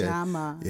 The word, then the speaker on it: Ujamaa: Ujamaa. Okay. – Ujamaa.